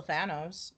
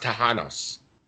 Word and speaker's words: Thanos: Well, they were trying to kill Thor, or they were trying to kill Thanos. And no matter what, in the chat, y'all gonna make fun of the way I pronounce it. – I think it's pronounced Tahanos.